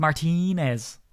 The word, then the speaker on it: Martinez: He was saying that I was pronouncing Blake Martinez's name wrong. It's Martinez. – It's always been Martinez, bro.